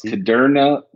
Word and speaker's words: Kudrna: It's pronounced Kudrna like Moderna. – Kudrna.